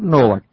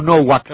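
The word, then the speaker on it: Nowak: la W alemana es una V. El profesor Novak, austríaco, acá le decimos Novak.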